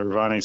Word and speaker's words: Ravani: In Greece, am I pronouncing this right? Ravani, it's got the vanilla, lemon zest, and even some rose water. – Yeah, the Ravani.